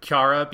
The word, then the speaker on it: Chara: It's I'm going to call her Kiara because that's how they pronounce it in Japanese.